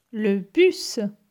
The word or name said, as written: Le bus